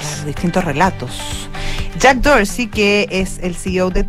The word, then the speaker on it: Dorsey: ¿Cómo se pronunciará? – Dorsey, si fuese como el Museo Parisino, el Museo d'Orsay.